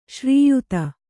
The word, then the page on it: ಶ್ರೀಯುತ: śrīyuta